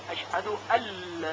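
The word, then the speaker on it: أن لا: پس در نوشتن این جمله نون ساکن و لام نوشته میشن، ولی در تلفظ و خوندن اونها حرف نون اصلا خونده نمیشه و فقط لام با تشدید شنیده میشه، اشهد ال.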